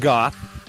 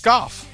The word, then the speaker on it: golf: You know there is an L. There is an L in that word, and you do pronounce it. You're supposed to. – With chains, we may golf. – You know. Yeah, I got it. – Come on, say it. You can say it properly, not goth.